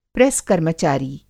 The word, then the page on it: प्रेस-कर्मचारी: Hindi